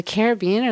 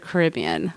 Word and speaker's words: Caribbean: The Caribbean or the Caribbean? – Both are acceptable.